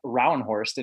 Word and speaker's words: Rauenhorst: We're here to talk with Jeff Rauenhorst.